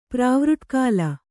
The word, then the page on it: ಪ್ರಾವೃಟ್ಕಾಲ: kannada